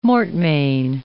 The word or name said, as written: mortmain